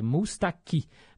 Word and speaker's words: Moustaki: É, aí ele falou que eu pronunciei errado, né, gente? Eu falei Georges Mustaki, é Mustaki. Do francês, né? Geralmente o francês, a sílaba tônica é a última.